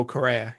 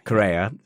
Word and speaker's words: Correa: and it was Carrera. I've, I've, said the name wrong, haven't I? – Juan Manuel Correa. – Correa uh, was the driver that ended up crashing into him, I believe.